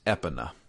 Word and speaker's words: Epona: So when I was a kid, I remember getting into a heated argument with another kid in my class. Because I apparently pronounced the horse's name wrong. I called it Epona as well. And he said, no, it's Epona. – Epona. – Yeah. I still don't know how to pronounce this horse's name. I still call it Epona. – I was kind of somewhere in the middle. I think my emphasis was on the pone rather than the E.